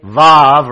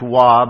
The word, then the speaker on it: Vav: So, Aleph, Beit, Gimel, Dalet, He, Vav, or some pronounce Wah, but I think Vav is the best pronunciation.